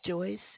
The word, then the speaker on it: Joyce: My name is Joyce. It's spelled J-O-Y-C-E.